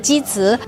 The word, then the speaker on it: Jizi: Según la leyenda coreana y también según los registros históricos chinos, alguien llamado Giya, en chino se dice Jizi,